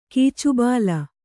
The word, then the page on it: ಕೀಚುಬಾಲ: kannada; kīcubāla